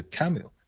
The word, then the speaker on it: Camus: 更极端的是另外一个这个存在主义大师，是法国的这个加缪，他英语叫 Albert Camus，但是法语里面这个 S 是不发音的，所以就 Camus 应该翻译成加缪比较合适。